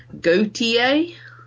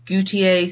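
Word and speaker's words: Gotye: It is Somebody I Used to Know by, we're not really sure how to pronounce his name, where there seems to be a lot of debate on how you say it, but I'm gonna say Gautier. – That sounds about right. – Gautier sounds like a fashion brand or gotcha.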